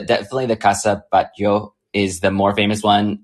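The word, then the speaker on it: Batlló: And the other one is Casa Batlau. Batlau or Batyau? – You're butchering it, but go for it. I don't know how to see it. – Casa Batyau. – No. Oh, there you go.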